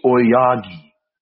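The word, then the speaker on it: Aoyagi: I think I caught part of that tag team match with Rising Hayato and Atsuki Oyagoi against Oyagi. Come on, bro. No, I watched the match with Dan. – No, I'm trying to be legit. How do you pronounce it? – It's Suki Oyagi. – Oyagi, got it.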